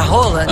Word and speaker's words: Haaland: Lembra que a gente falou sobre a pronúncia do nome do Haaland, que é a Roland? – Aham.